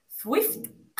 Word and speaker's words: Zwift: Bueno, pues de bici estática, de bici estática hay unos cuantos, pero hay uno, no sé cómo pronunciar esto, Zwift. – Vale, lo... Zwift.